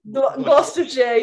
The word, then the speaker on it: Gloucestershire: But anyway, so your summers in Nashville, because you grew up in England then, and I'm going to probably butcher this, but like, was it like Gloucestershire? – Gloucestershire, yeah.